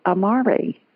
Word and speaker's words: Amaury: A-M-A-U-R-Y. – Amaury, but you roll your R's, Amaury. All right, so yeah, we roll them pretty good.